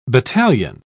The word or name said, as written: battalion